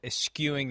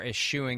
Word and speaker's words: eschewing: Saquon Barkley, 22 carries for 83 yards and a touchdown, and eschewing the opportunity or eschewing the opportunity, depending upon your preferred pronunciation, to score another touchdown late.